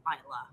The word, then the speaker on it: Islay: But if you're not jetpacking to our secret island, you might go to Isla. So it is pronounced Isla, which yes. – we did mention briefly before. – Islay.